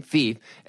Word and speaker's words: Phoef: Thief Sutton, and of course he pronounces his name Thief and spells it P-H-O-E-F, is really a Renaissance man.